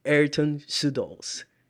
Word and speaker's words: Ayrton Sudols: Please welcome the legend, Ayrton Sudols.